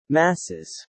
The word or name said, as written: masses